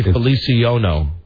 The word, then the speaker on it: Feliciano: When you get down to Jose Feliciano, you spelled his name wrong. – Oh, no. – You spelled it F-E-L-I-C-I-O-N-O. Jose Feliciano. – It's Feliciano.